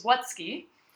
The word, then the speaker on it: Wutzke: But my last name is Wutzke, and instead of writing Wutzke, which is W-U-T-Z-K-E, they wrote Wutake. So they wrote W-U-T-A-K-E.